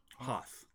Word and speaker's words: Hoth: I guess you pronounce it Hoth? – Hoth.